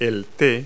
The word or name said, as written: tea